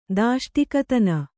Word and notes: ದಾಷ್ಟಿಕತನ: kannada

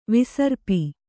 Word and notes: ವಿಸರ್ಪ: visarpa

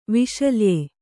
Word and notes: ವಿಶಲ್ಯ: viśalya

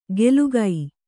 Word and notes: ಗೆಲುಗೈ: gelugai

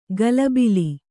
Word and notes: ಗಲಬಿಲಿ: galabili

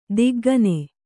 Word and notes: ದಿಗ್ಗನೆ: diggane

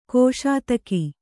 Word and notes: ಗಸಿತೆಗೆ: gasi tege

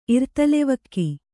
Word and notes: ಇರ್ತಲೆವಕ್ಕಿ: Kannada

